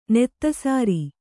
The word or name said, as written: ನೆತ್ತಸಾರಿ